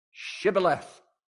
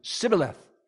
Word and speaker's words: Shibboleth: They said, say Shibboleth. And because they couldn't pronounce it, they'd go, Shibboleth. They couldn't make the sh sound.